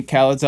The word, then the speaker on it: Khaled: Khaled has an E and then Khalid has an I. So it's a little different, but there's definitely Khaleds out there that are pronounced like Khaled and not Khalid.